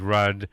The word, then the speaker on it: Rudd: And that's, you know, and people would always mispronounce my grandfather's name because it's R-U-D-D, Rudd, and they would say, oh, your grandfather's rude, isn't he? I said, no, he's sometimes abrupt, but never rude.